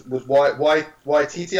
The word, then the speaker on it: Waititi: So the director of that, the director of Last of the Thor Ragnarok was Waititi, I think you pronounce his name.